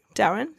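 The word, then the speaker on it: Delrin: but like Dalren, is that how you pronounce it? – Yeah.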